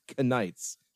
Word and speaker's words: Knights: It's consistently pronounced Knights. Like, they pronounce the K.